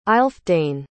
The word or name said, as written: aelfdane